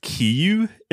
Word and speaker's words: Kiryu: I know he's pronounced Kiyu in the dub. – Yeah. We all watch the English dub, right? – Yeah. – I've always thought he was called Kiryu, but in the dub they're just so confident in saying Kiyu. – Yeah. – K-I-R-Y-U. – But they say Kiyu. They're like marching around with their little chant, the little Kiyu chant.